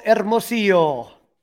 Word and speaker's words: Hermosillo: They DFA'd one of the guys that I love how you say his name. So I'm going to let you go ahead and say his name. Who did they DFA? – Michael Hermosillo. – Very good. Almost as good as Pat Hughes rolling that H.